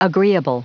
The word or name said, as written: agreeable